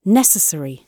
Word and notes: necessary: British English

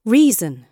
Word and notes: reason: British English

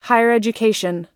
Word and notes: higher education: American English

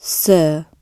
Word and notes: sir: British English

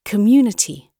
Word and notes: community: British English

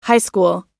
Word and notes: high school: American English

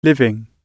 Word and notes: living: British English

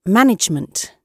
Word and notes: management: British English

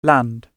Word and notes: land: British English

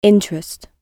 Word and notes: interest: British English

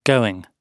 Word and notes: going: British English

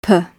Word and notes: per: British English

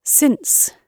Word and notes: since: British English

